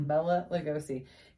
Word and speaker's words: Bela Lugosi: So, Dracula is played by Bela Lugosi. Is that how you pronounce his name? – Mm-hmm. – Bela Lugosi.